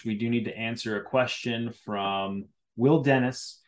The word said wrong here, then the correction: Denis: It's pronounced Denis. It's French.